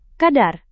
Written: kadar

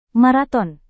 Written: maraton

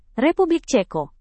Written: republik ceko

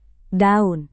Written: daun

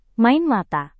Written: main mata

